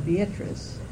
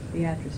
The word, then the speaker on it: Beatrice: It's B-E-A-T-R-I-C-E. Mm-hmm. And usually it's correctly called Beatrice, but the woman for whom it was named called herself Beatrice. So that's how that name stuck, Beatrice McKinney, who was the daughter of one of the early governors.